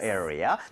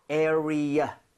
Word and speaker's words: area: area. 대신에 발음할 때 전에 말씀드렸죠. 자기도 모르게 area. 이렇게 해서 R 사운드 내는 경우 많다. Right. 그래서 맞아요. – area 하고 완전 끊어내세요. R 하지 마세요.